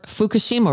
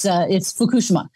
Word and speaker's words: Fukushima: You know, I talked to a Japanese person and they said it's Fukushima. – Fukushima. – But, you know, Fukushima.